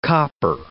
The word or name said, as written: copper